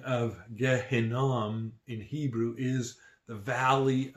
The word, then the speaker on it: Gehinnom: In Hebrew, the valley of Hinnom is pronounced Gehinnom, from which the name Gehenna comes from.